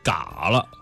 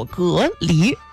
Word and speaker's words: 蛤蜊: 嘎了，哈啤酒吃嘎了。 – 有人说嘎了，有人说蛤蜊。其实它正规的那个规范读音叫做蛤蜊，就是大家这都是类似花甲河畔的那种东西。